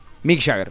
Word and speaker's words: Mick Jagger: ¿Sabes que hoy es el cumpleaños de, Mike Jagger? ¿Mike Jagger se dice? No sé cómo se dice. Mick Jagger. – Mick Jagger.